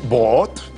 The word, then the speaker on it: Brot: Brot, des ich essen brot. Puis il faut que tu le prononces vraiment ben, brot.